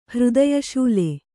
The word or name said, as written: ಹೃದಯಶೂಲೆ